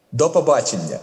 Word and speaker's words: до побачення: and that word is «до побачення». Did I say it right? – Uh-huh. – How do you say it? – «До побачення». – «До побачення».